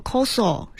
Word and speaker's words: 酵素: 嗯，啊，它的那个日语发音叫 koso，是其实就是酶。